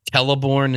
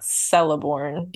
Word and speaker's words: Celeborn: I'm just really proud that I know how to say the C's right. – Oh yeah. Teleborn instead of Celeborn, yeah. For example. – Yeah, it's not Celeborn.